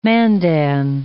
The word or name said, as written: Mandan